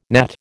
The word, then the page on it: gnat: American English